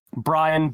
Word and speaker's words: Brian: Uh, Brian B R I A N Beversluis B E V E R S L U I S.